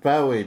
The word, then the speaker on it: Bowie: It says here that there was a rumor that David Bowie. – Bowie. Mm-hmm. It's pronounced Bowie, darling.